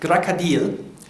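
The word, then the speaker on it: Krokodil (крокодил): Empfohlene Aussprache ist Krokodil mit der Betonung auf dem I. Die einfache Faustregel ist, unbetonte O's werden wie A's gesprochen.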